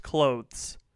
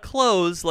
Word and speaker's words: clothes: okay, my strongest opinion is that the word clothes is pronounced clothes. Not clothes, like close the door.